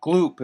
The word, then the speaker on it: Gloop: Gloop is my last name.